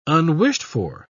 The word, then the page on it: unwished-for: British English